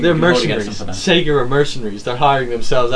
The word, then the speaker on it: Sega: Sega.